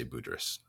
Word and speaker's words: Budrys: Brandon Melvin on Facebook sees an Aldous Budrys. Am I pronouncing that name right? – I have heard people who seem like they should know say it differently, but most people I've heard say Budrys.